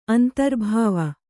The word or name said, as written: ಅಂತರ್ಭಾವಿ